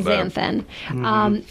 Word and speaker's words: Astaxanthin: But we want to talk about a product called Astaxanthin, which is say that 12 times I was going to say, well done on the pronunciation on that one. – Astaxanthin.